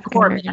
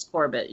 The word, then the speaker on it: Corbett: I'm forgive me if I mispronounce, but Kazmikia Corbett. – Corbett. I think it's Corbett, yeah.